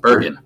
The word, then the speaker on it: Bergen: He was at Bergen Catholic, I think that's how you pronounce it? – Bergen. – Bergen Catholic, mm-hmm.